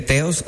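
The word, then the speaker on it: Theos: Y dije, como mi nombre se escribe Teos, se escribe T-H-E-O-S, y yo dije, voy a escribir Tessa con T-H igual, y dos S.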